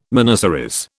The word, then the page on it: miniseries: British English